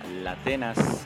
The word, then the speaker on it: Latenas: ahí, con todo su delirio, tuvimos a la señora Latenas. Suena como La Atenas o Latenas, pero se escribe todo junto.